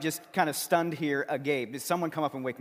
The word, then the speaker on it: agape: It's described by the word agape. That's how you pronounce it in the Greek. We have known it as agape. You can pronounce it any way you want. It's just not agape, right? That's a whole different thing.